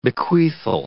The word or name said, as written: bequeathal